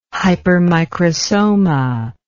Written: hypermicrosoma

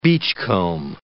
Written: beachcomb

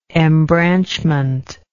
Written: embranchment

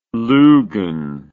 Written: loogan

